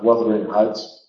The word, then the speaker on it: Wuthering: By the way, Heathcliff in Wuthering Heights, which is pronounced Wuthering Heights, not Wuthering or anything like that, Wuthering Heights, is another Byronic figure.